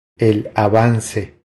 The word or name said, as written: El avance